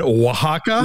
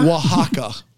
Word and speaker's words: Oaxaca: You mean Oaxaca? – Oaxaca. Oaxaca.